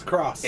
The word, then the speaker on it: cross: PlayStation has announced officially that we've been pronouncing the name of the button wrong since PS1's inception. It is not the X button. – It's cross. – It is the cross button.